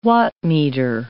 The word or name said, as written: wattmeter